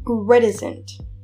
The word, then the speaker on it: reticent: reticent. That's how you say it. Okay, for the word I was tripping over, it's R E T I C E N T.